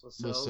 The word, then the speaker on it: Lascelles: Lascelles.